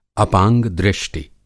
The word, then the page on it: अपांग-दृष्टि: Hindi